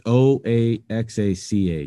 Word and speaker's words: Oaxaca: It's O A X A C A cheese. Quesillo. – Oaxaca. – Oaxaca. Quesillo. Yeah.